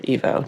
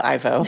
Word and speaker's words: Ivo: St. Ivo, or Evo, depending on how you say it, but I'm going with Ivo, and it became this kind of almost like talismanic thought for the character as she moves through the a couple of days.